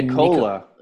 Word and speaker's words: Nikola: Nikola.